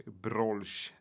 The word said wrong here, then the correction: Borsch: eller hur uttalar man det? – Borsch. Borsch. Eh, den är mycket populär att äta i Lettland också, precis ja.